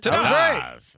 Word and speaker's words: Tadas: How do you think you pronounce the name T-A-D-A-S? – Tadas.